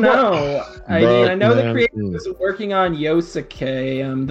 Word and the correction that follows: Yasuke: Yasuke. – Yasuke, yeah.